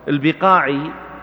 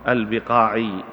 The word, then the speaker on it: البقاعي: البقاعي بعض الناس وكثير من الناس ينطق اسمه بالبقاعي بضم الباء، وهو غلط، نسبة إلى البقاع، البقاع في لبنان، هو لبناني رحمه الله برهان الدين، هو لبناني من لبنان، من أرض يقال لها البقاع بجوار بيروت.